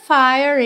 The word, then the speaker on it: fire: Fire, o E final não é pronunciado.